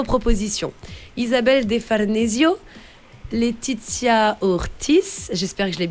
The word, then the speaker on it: Letizia: C'était Letizia, bien sûr.